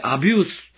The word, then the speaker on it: abuse: さて、この夫が妻を殴ったり、親が子供を虐待したり、あるいは子供が年老いた親を虐待したりすることを英語でアビュースと言うそうです。 ABUSE と書いてアビュースと呼ぶんですね。